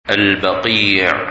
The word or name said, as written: Baqi